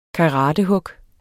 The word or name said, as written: karatehug